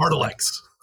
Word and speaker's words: Artilects: The name of your book is Our Lady of the Artelex. I'm saying that right? – Artelex. – Artelex. Yeah. Artelex. Yeah, there you go.